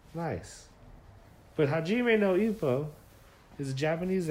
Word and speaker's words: Hajime no Ippo: But it's a Japanese anime called, and this is in Japanese, so, you know, not a native speaker at all, but we're pretty sure it's pronounced Hajime no Ippo.